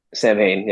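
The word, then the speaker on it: Samhain: What's the Sal Ham? How do you say that? – Sam Hain, yes.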